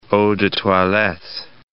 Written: eau de toilettes

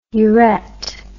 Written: uret

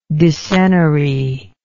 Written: decennary

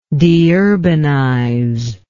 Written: deurbanization

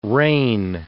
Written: reign

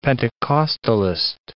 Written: Pentecostalist

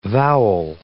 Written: vowel

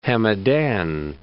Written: Hamadan

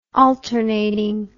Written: alternatingly

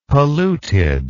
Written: polluted

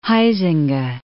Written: Huizinga

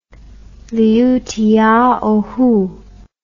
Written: Liutiaohu

